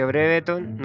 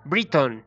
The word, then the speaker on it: Brereton: Breveton, Breveton, Bredeton, Briton. Así se pronuncia realmente.